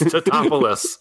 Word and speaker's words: Tatopoulos: Now we almost get that with, you know, Nico Tatopoulos, Tatopole. – Oh boy. – Guy. – It's Tatopoulos.